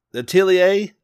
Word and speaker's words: Atelier: At- At- Atelia Ryza. Atelier Yes.